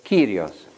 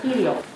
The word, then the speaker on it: Kyrios: The Greeks pronounce that word Kyrios. – Und die Griechen sagen Kyrios.